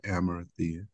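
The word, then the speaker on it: Arimathea: Amar- uh, Judean town Oh, Okay, you said it right, Amarathia.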